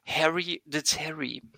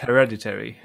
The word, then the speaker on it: Hereditary: Ähm, ich meine aber, der Film, der, wenn ich ihn falsch ausspreche, und das tue ich immer, so klingt wie ein 80 er jahre kopf mit Schnauzbart. und zwar Harry the Terry. Ähm, auch bekannt als Hereditary, ja.